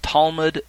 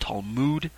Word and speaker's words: Talmud: Sometimes you'll hear it pronounced Talmud, sometimes you'll hear it pronounced Talmud.